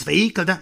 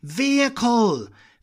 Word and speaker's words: vehicle: No es vehículo, es vehicle. V. A ver esa V. Vehicle.